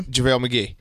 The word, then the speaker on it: JaVale McGee: JaVale McGee.